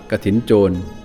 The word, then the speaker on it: กฐินโจร: กระถินโจร